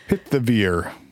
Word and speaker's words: pithivier: Isn't there like an H in there or something, though? – Yeah. Yeah. – Okay. – Petivier. I'm not sure if the H is silent or not. – Pithivier. – Yes. – Pithivier. – A pithivier. Yes.